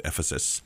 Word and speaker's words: Ephesus: You have to learn how to pronounce that, Ephesus. – Ephesus. – And put the emphasis on the Ephesus, okay? – Yes.